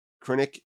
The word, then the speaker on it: Krennic: I know it's pronounced Director Krennic, but yeah. Vader pronounced it Director Krennic.